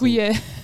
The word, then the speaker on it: Huillet: Um, I'm not sure if I pronounce Huillet.